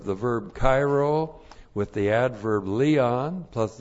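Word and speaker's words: kairo (χαίρω): First of all, we have the verb kairo. Looks like this in the Greek. That's C-H-A-I-R long O, kairo.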